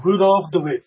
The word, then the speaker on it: Rudolph DeWitt: Rudolph DeWitt. That's it. – Rudolph DeWitt. Because some people, I've heard, you know, from the Netherlands, I sometimes hear the W as a V, and I'm not, you know, I'm not confident yet in saying it properly.